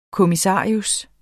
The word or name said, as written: kommissarius